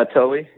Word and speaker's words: Towee: I know Reddington Towie Boats. Am I saying that right? – Yeah, Towie. Yeah, T-O-W-E-E, Towie boats, Plan D fly boxes.